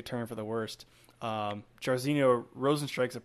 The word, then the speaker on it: Rozenstruik: Rozenstruik.